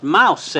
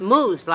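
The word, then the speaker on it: mousse: That's pronounced mouse." – "No, it's pronounced moose, like Mrs. Whitney said."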